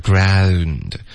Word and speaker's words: ground: I may be a sissy, but I'll still pound you in the ground. – Okay, ground 不是这个发音，是 ground。开头的 I 是一个双元音，不是 I。